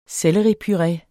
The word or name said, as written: selleripuré